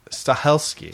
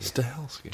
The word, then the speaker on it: Stahelski: Stahelski. – Stahelski. – Chad Stahelski. You say it fast.